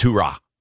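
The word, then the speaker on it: hurrah (ὥρα): And it's pronounced hurrah, almost like a Marine chant.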